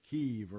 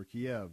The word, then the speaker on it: Kyiv: We've had airlifts out of Kyiv or Kiev, depending on how you pronounce it, trying to bring people to safety.